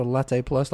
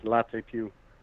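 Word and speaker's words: Latte+: So Latte Plus, like Milk Plus? – Yeah. – Yeah, but it's pronounced Latte Pew. Like it's Italian, obviously.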